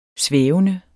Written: svævende

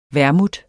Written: vermouth

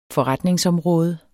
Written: forretningsområde

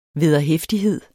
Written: vederhæftighed

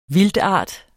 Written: vildtart

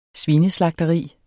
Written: svineslagteri

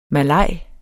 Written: malaj

